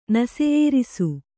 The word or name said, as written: ನಸೆಯೇರಿಸು